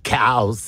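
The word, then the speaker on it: Cowes: Cows. – Cows. It's spelt with an E.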